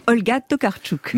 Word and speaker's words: Olga Tokarczuk: Olga Tokarczuk.